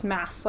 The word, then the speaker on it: Mads: I looked up the pronunciation of Mads Mikkelsen's name. – Uh-huh. – And it's just Mass, like M-A-S. – Really? – It's like the last syllable of Christmas or Thomas. It's just like Mass.